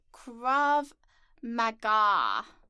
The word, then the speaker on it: Krav Maga: And it is pronounced Krav Maga.